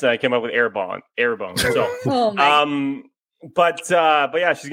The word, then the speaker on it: Arbonne: It's called Arbonne.